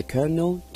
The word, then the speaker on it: Colonel: 在这里呢是要做一个更正，上教的英文读音呢是 Colonel 不是 Colonel。好多谢三毛的指正，请各位听众留意啦， Tom Parker 上教呢是 Colonel Tom Parker。